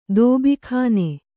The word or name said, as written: ದಬಕ್ಕನೆ